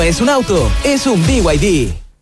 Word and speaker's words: BYD: Se escribe B&B, pero se dice BYD.